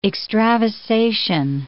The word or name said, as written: extravasation